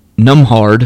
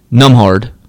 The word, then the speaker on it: Nembhard: One, Andrew Numhard. I think that's how you say it. It's spelled N-E-M-B-H-A-R-D. Numhard. I think that's how you would say it.